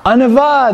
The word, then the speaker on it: Anavah: Is it Anava? – Anava, that's right. And it's spelled A-N-A-V-A-H. Anava.